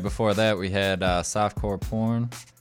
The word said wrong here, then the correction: Soft Pour Corn: I said that wrong. I'm so sorry, radio. Soft Pour Corn.